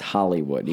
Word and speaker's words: Hollywood: No, not you just said that wrong. It's Hollywood. – Hollywood? – You gotta say it right.